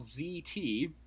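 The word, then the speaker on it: Zhi Tea: They're like, oh yeah, it's this company, it's called ZT, which is spelled ZHIZT. – I have to look this up while you're talking about it just because I need the visual. – Oh, please do. – You said ZHI, but it's pronounced ZT.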